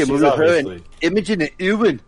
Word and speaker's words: Imogen: And it's got our boy Jesse Serra, Imogen Poots. And that's about it. There's not much. – Yeah, how do you pronounce her first name? – Imogen. – Imogen. – It's like, yeah, Imogen.